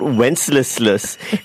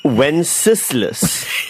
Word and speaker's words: Wenceslas: So, according to a few text messages, you're still mispronouncing it. It's not Wenceslas, it's Wenceslas.